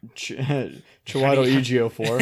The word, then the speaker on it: Chiwetel Ejiofor: Chiwetel Ejiofor. – Is that how you say it? – Yeah.